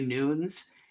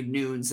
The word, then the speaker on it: Nunes: and the last name is spelled N-U-N-E-S. So it's pronounced a little differently apparently than you would think it is. Cause everybody always, I think, says Nunes. And I actually said Nunes for years before Todd was very kind about it.